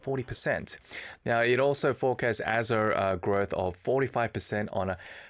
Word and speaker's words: Azure: Yeah, Azure, okay. Like pressure.